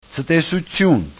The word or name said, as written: ctesutyun